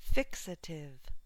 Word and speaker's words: fixative: Fixative, first syllable stress.